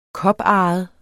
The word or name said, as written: koparret